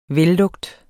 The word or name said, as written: vellugt